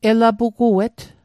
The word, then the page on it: elapuguet: Mi'gmaq